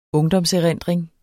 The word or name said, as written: ungdomserindring